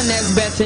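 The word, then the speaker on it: Nesbeth: I never hear of a Nesbitt in Jamaica. – B-E-T-H.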